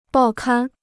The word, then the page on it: 报刊: bào kān